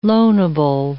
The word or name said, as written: loanable